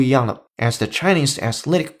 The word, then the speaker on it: athletic: athletic，注意它的发音，athletic，athletic 啊，这是一个跟 athlete 重音放的不一样地方的单词，一定要注意这一点。